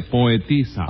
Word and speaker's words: poetisa: Es un poetizo, ¿no? No se dice poetizo, Tres Patines, se dice poetisa.